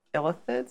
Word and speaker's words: Illithids: that my people's ancestral task was to fight Illithids.